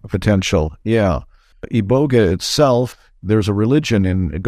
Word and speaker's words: iboga: People pronounce it iboga. I pronounce it iboga, which spelled I-B-O-G-A.